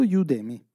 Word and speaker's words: Udemy: Ondemi, giusto? Si pronuncia così? – Eh, credo Udemy.